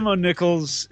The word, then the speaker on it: Mo Nichols: Yeah, which is kind of like a play on monocles. – Gotcha. – DJ Mo Nichols is somebody I actually met when we did the No Friends tour.